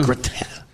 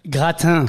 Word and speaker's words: gratin: The gratin. How do you say it? – Gratin. – Gratin. Exactly.